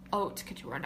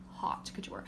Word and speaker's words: haute couture: Okay, I meant to say haute couture, not hot couture. But that's like how it's spelled. – It's literally spelled haute couture.